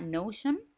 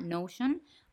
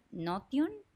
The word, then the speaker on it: Notion: así que se llama Notion, o en español o sea, si la pronunciara como español, Notion, como N-O-T-I-O-N.